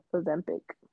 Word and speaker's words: Ozempic: Ozempic.